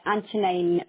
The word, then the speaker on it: Antonine: It's Antonine Medical Practice. – How are you spelling that one? – A-N-T... – Yep. – O-N-I-N-E. Yep. – That's how it's pronounced, yeah, medical practice.